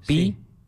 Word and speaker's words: Pigüé: Es un nombre indio. Pi-HV. No se escribe así, se escribe con G. Y diéresis en la U.